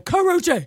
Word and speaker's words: Karrueche: Karrueche. – You ain't say it right. Karrueche.